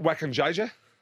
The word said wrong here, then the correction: Wakajija: Wakajija. – What, yeah. Wakajija? – Wakajija.